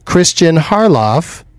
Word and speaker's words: Kristian Harloff: Kristian Harloff.